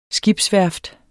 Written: skibsværft

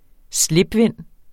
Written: slipvind

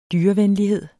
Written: dyrevenlighed

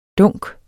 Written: dunk

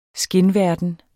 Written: skinverden

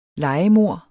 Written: lejemord

